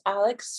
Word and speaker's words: Alyx: My name is Alex Starr.